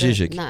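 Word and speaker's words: Žižek: Eu, eu gosto do, do que Na verdade, se escreve Z-I-Z-E-K, mas aí a leitura. – Zizek.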